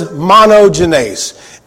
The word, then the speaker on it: monogenes: That word is monogenes, M-O-N-O-G-E-N-E-S. And I'm pretty sure I'm pronouncing that correctly, because one great thing about Blue Letter Bible is they have a guy that says it.